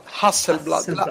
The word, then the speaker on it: Hasselblad: Hasselblad.